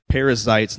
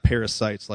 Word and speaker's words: Perizzites: It says, then Judah went up, and the Lord gave the Canaanites and the Perizzites, like Perizzites, not parasites, like a bug, into their hand, and they defeated 10,000 of them at Bezek.